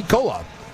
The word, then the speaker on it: Kolob: Oh, K-O-L-B, Kolob.